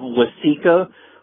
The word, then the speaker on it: Waseca: But I'd just like to say that your, pronunciation of, the beautiful, South Central Minnesota town of Waseca was correct.